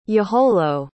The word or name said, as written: yaholo